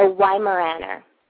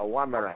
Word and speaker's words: Weimaraner: It's a blues called a Weimaraner. – A Weimaraner.